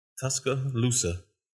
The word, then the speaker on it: Tuscaloosa: How you say it? – Tuscaloosa. There you go. – I don't know why I want to put another C on the end of that.